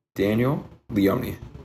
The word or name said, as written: Daniel Leone